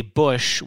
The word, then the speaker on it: Boesch: Yeah, you wouldn't expect his last name to be pronounced Cheddar based on how it is spelled, T-S-C-H-E-T-T-E-R. But as a Bush with B-O-E-S-C-H as the spelling of my last name, there's something that we can certainly relate to on that.